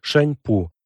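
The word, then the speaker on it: Шаньпу (珊璞): Например, имя китайской невесты Ранмы Сао Томе транслируется как Сианьпу, Шаньпу, и произносится близко к шампунь.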